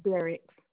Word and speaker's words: Derricks: And my last name is Derek. – How do you spell it? – D as in dog, E-R-R-I-C-K-S.